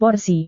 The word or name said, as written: porsi